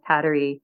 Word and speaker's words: Kateri: Recently, I was on a retreat with a St. Kateri, it's pronounced Kateri, conservation center up in New York State.